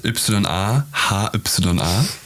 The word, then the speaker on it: Yahya: Buchstabiert Y-A, H-Y-A. Und das ist eigentlich, ursprünglich ist das ein arabischer Vorname. Ja, ein arabischer Vorname, der wird eigentlich Yahya ausgesprochen und übersetzt bedeutet das am Leben sein, lebendig sein, am Leben teilnehmen und das Leben zu zelebrieren.